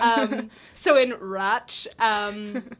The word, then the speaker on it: Radch: how do you say the language? Radch? Roch. Roch. Yeah.